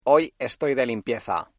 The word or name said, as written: limpieza